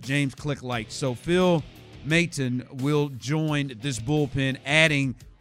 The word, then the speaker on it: Maton: Pronunciation, M-A-Y-T-A-H-N, Maton.